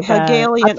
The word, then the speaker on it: Hegelian: Hegelian. – Okay. I told you that's how it's pronounced before I started.